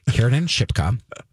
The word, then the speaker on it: Kiernan Shipka: It stars Kiernan Shipka. Apparently I'm saying her name wrong lately. Kiernan Shipka, who is in this town, a very suburban town.